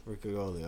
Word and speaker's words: riccioli: The ricola? – I thought that was the riccioli. – Riccioli, oh, okay.